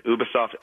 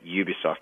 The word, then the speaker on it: Ubisoft: They pronounce it both ways, Ubisoft and Ubisoft, because it's a French company.